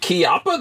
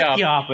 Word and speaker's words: Chiappa: Is it Chiappa? That's how you're supposed to say it. It is Chiappa. It's Italian. It's pronounced Chiappa.